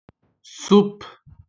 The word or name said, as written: soupe